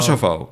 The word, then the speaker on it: Kosovo: dat je bezig was met een investeringsproject, zeg ik het goed, in Kosovo. – Kosovo. – Inderdaad.